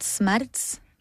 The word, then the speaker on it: Smerz: We pronounce it Smerz, but it's up to you.